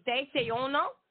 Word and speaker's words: desayuno: So today, the word for the day is desayuno. And if you're ready with your pen and paper, it's spelled D-E-S-A-Y. U-N-O, desayuno, or like D-say-uno.